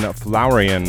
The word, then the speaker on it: Flowerian: And he says he's fairly certain the flow in Flowerian is pronounced like flower, not the word flow.